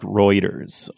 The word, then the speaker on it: Reuters: This is from uh, Reuters. No, I'm kidding. It's pronounced Reuters.